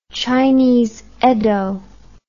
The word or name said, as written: chinese eddo